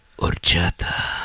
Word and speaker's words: Horchata: Horchata. – Oh, you took the last one? Punk. – Did I say it right? – It's Horchata. – You gotta have the heavy breathing at the end.